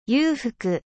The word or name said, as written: 裕福